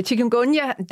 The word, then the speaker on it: Chikungunya: Så Chikungunya, nu fik du sagt det først, det var dejligt, for jeg vidste ikke, hvordan jeg skulle udtale det, men det lyder godt, når du sagde det så.